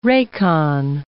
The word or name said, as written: racon